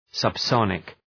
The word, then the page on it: subsonic: səb’sɒnık